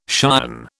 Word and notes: cion: British English

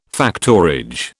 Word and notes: factorage: British English

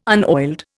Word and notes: unoiled: Indian

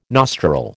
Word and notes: nostril: American English

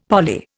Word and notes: poly: Indian

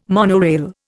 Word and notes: monorail: Indian

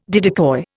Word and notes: didicoi: Indian

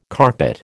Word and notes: carpet: American English

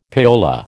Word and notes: payola: American English

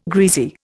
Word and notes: greasy: Indian

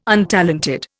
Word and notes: untalented: Indian

